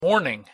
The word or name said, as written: morning